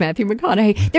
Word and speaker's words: McConaughey: I learned that from Matthew McConaughey, who by the way, I can finally remember how to say his name because he says it rhymes with what would Madonna say? It's Matthew McConaughey.